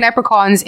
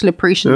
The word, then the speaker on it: leprechauns: Like, wait, what on God's green earth makes you think leprechauns is pronounced Lapretians?